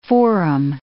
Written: forum

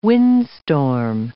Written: windstorm

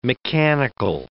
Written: mechanical

